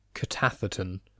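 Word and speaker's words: K'tath'atn: So to try and pronounce the Queen's name, I believe it is Katathaton,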